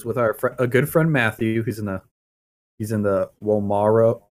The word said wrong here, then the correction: Yomawari: How do you pronounce that game? – Yomawari Night Alone.